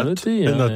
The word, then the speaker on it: Nat: on prononce bien noté, Absolument.